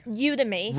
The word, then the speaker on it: Udemy: Udemy.